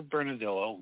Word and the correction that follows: Bernalillo: You mean Bernalillo? That's how you pronounce Bernalillo.